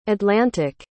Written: atlantic

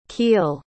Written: Keele

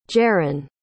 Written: jaron

